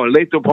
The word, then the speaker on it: Lathrop: La- Lathrop houses? How do you? – Oh, Lathrop homes.